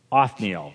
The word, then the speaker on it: Othniel: the first guy, Othniel is how his name is pronounced.